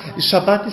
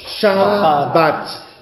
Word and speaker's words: Schabbat: Schabbat ist der eigentlich jüdische Begriff. Schabbat. Heute ist Schabbat. Können wir das einmal aussprechen? Schabbat. Schabbat. Genau. Und Sabbat ist die Verdeutschung.